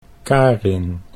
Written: Karin

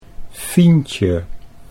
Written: Fientje

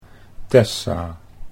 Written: Tessa

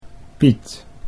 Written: Piet

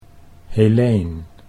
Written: Heleen

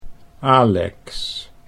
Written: Alex

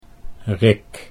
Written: Rick

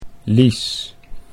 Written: Lies

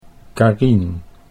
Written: Carien

